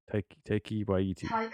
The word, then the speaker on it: Taika Waititi: How to pronounce Taika Waititi.